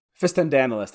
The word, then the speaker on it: Fistandantilus: Now I, I, know you've you've actually been asked by fans many times about this idea of fistandulus. Uh, fist, how do you pronounce it? Fist, fist, fistandulus or fistandalus? – Fistandantilus. There we go.